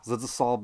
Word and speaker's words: Zdzisław: Zdzisław Baksinski.